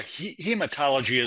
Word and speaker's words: hematology: He uses a term here called hermatological. What does that mean? What does that mean? – Yeah, hematology is just blood. – Thank you for pronouncing it correctly for me.